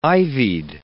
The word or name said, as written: ivied